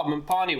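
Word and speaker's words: mopane: We have dried mophane worms. – Oh, mpani worms. – Mpani worms. Oh, I said it wrong.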